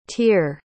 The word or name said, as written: teer